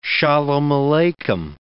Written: shalom aleichem

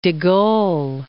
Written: Gaulle, de

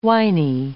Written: winy

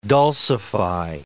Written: dulcify

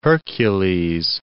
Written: Pillars of Hercules

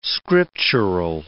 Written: scriptural

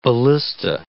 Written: ballista

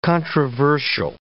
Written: controversial

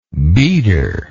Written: beader

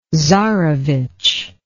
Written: tsarevitch

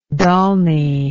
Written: Dalny

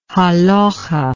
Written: halachah

